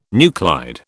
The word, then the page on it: nuclide: British English